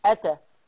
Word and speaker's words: أتى: يبقى الكلمة الأولى أنا عايز عينكم معايا قوي، الهمزة على ألف، إذا ما فيش مد، أتى آ آ أتى، بنتقل من همزة فتحة آ إلى تاء أتى. لكن الكلمة الثانية همزة ثم ألف، يعني الهمزة هتنطق وبعدين الألف تأخذ تقرأ بمقدار حركتين آتى آتى.